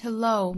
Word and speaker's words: hello: 第三句 You had me at hello, me 和 at 又是元音和元音的连读，同样带出小的 e。 You had me at hello。 Hello 这个词重读在后面这个音节，就像最后一个词 goodbye 重读也是在后面一样。